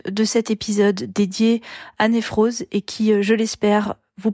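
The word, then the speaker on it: Newroz: Donc la fête c'est Névros, dans toute la variété ça se prononce Névros.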